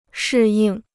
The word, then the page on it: 适应: shì yìng